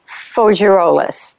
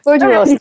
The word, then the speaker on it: Faugerolas: All right, we're going to take a break, and when we come back, we are going to talk to Marie-Ange Faugerolas. Did I say that right again or wrong? – Perfectly, Patricia. – Perfect. Faugerolas.